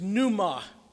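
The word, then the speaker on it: pneuma (πνεῦμα): The word spirit in Greek is pneuma, the silent P, just like psyche, from which we get pneumonia or pneumatic.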